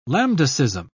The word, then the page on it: lambdacism: British English